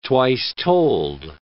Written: twice-told

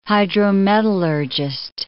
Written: hydrometallurgist

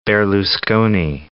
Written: Berlusconi

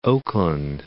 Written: Oakland

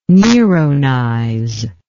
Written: neronize

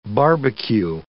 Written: barbecue

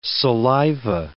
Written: saliva

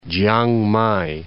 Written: Chiang Mai

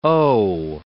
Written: owe

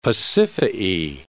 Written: Pasiphae

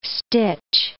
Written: stitch

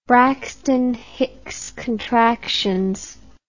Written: Braxton Hicks contractions

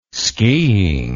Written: skiing

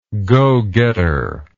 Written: go-getter

